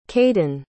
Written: kaiden

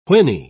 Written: whinny

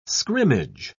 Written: scrimmage

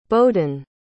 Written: bowdyn

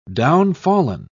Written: downfallen

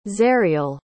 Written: zariel

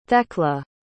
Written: thekla

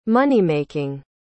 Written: money-making

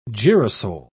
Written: girasole

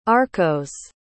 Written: arkose

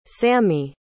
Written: sammie

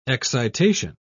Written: excitation

